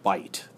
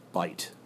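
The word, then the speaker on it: by't: The soldiers that were forced to fight, if they survived, got honor bite. That's how it would have been pronounced at the time. That is by it, but you see the apostrophe. So the soldiers that were forced to fight, if they survived, got honor by it, bite.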